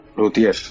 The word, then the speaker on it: luthier: O, o no sé cómo se pronuncia exactamente. – Lutier, yo siempre lo pronuncio Lutier.